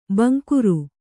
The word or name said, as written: ಭಿಂಗಾರಿ